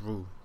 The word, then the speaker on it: Rhule: Then the Panthers got Matt Rhule The E is silent. Just Rhule. – Okay. – Like Ja Rule.